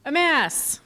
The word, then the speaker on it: Emmaus: Am I saying that correctly? – Emmaus. – Emmaus? – Emmaus.